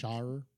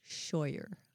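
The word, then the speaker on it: Shawyer: I bet that rhymes with lawyer because it's spelled like Shire. – It's spelled like Shire, lawyer with an Shire, SH. – Shire. – Shoyer. – Shyster. No, Shire. – Roger Shoyer.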